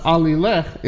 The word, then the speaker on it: Alileche: Alileche is how John is pronouncing him.